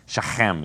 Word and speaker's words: Shechem: So Shechem is how they pronounce it. So there you go. Hockey, a good loogie in your throat and you're saying it correctly.